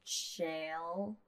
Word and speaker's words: Chael: Well, okay, then what would you, how would you say it? – Not. – C H A E L. Andy. – Shale.